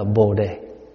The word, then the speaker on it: Bồ đề: Bodhi mình đọc là Bồ đề.